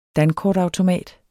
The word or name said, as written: dankortautomat